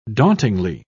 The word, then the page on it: dauntingly: British English